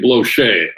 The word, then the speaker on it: Blocher: A great one is by Henri Blocher, a French man. Mm. His name is spelled H-E-N-R-I, and the last name is B-L-O-C-H-E-R, but it's pronounced Blocher.